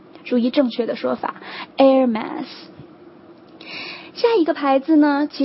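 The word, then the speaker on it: Hermès: OK，第三个我们要看的也是现在最炙手可热的一个牌子，爱马仕哈，爱马仕正确的发音是 Hermes，这个第一个音其实就是跟空气 air 的音是一样的， Hermes，这是一个法语哈，但是 Hermes 这个是在所有的英语国家当中最最广为接受的一种发音的方法， Hermes。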